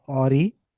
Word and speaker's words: awry: Okay, guys, from here on, the order of the super chats are going to be a little awry because we missed a couple of them and then we managed to retrieve It's pronounced awry. – Funny.